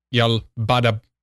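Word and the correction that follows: Yaldabaoth: Sorry, I'm mispronouncing that name. Yeah. – Yal-Dabaoth. – Yal-Dabaoth. That's right.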